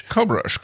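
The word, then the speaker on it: Cockburn: Cobrush? – Cobrush. – Isn't that how you pronounce it? – Artist known by the name of Bruce Coburn, so I guess it's Cobrush Avenue.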